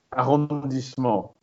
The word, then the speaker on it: arrondissement: You'll probably correct my pronunciation here, but arrondissement neighborhood, basically. What's the French word? – Arrondissement. – Yeah, I had no chance.